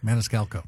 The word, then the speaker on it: Maniscalco: I always pronounce his name wrong. Maniscalco. – That last C that always gets me, too.